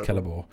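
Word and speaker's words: Celeborn: I think his name's Celebor. It's pronounced Celebor.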